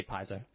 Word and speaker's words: Paizo: Pathfinder was first published in 2009, and this is how I'm going to pronounce it. Paizo? – I say Paizo.